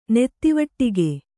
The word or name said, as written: ನೆತ್ತಿವಟ್ಟಿಗೆ